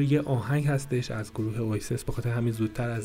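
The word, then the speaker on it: Oasis: اویسس بعضی هم اینجوری تلفظ میکنن که اواسیس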